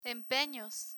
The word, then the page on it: empenos: Spanish